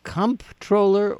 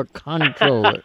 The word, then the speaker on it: comptroller: Is it comptroller or controller? – Well, the age-old debate. So in New York, by constitution, it's comp, C-O-M-P. But I'm told that pronouncing it like it's spelled con, C-O-N, is totally appropriate.